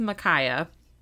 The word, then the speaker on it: Micajah: Okay, so Micaiah Harp, which, by the way, his name is spelled M-I-C-A-J-A-H, and so literally the entire time I was writing my notes, I was just saying his name, Micajah, which I knew couldn't be right, and I was just too lazy to look it up. It's Micaiah. It's spelled Micajah.